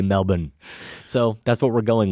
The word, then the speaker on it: Melbourne: And speaking of Melbourne, Australia, Mike sent us a lovely note and was very impressed with the fact that we pronounced it properly and not Melbourne like most Americans would.